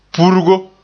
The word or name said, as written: purgo